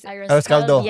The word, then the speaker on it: arroz caldo: Like, she even made me, like, was it arroz calor? Which is arroz caldo.